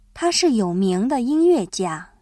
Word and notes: 有名: yǒumíng

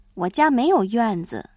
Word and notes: 院子: yuànzi

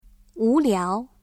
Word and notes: 无聊: wúliáo

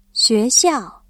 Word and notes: 学校: xuéxiào

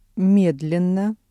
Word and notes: медленно: Russian

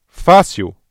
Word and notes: fácil: ブラジルポルトガル語

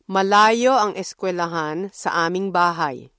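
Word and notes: eskuwelahan: Filipino

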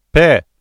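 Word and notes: pé: Brazilian Portuguese

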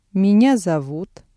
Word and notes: меня зовут: Russian

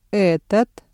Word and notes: этот: Russian

